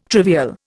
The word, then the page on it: trivial: Indian